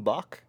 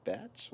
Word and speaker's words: Bach: Is that how you say it? – King Batch, yeah. – I always thought it was King Bach. Like, thank you. Okay. – At the beginning, I thought it was, like, Bach.